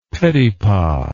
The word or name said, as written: petipa